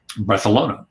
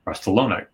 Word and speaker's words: Barcelona: And I had heard, that, you know, when the Spanish language in Barcelona, they there's a lisp to it. It's like Barcelona. When the C, which we pronounce as like an S sound, they pronounce with a th sound. So Barcelona, you know.